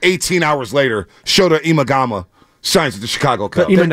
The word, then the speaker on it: Shota Imanaga: It's Shota Imanaga. – Shota Imanaga.